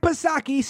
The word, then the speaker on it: Psaki: Now, if missing the numbers wasn't bad enough, when asked about it, Jen Circle Back Psaki, Silent P, of course, she says, well, I can't really talk about that until maybe around 9:30 in the morning.